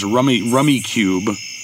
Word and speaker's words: Rummikub: Or pronounced Rummy Cube, and trying to avoid the curse of the red two tile.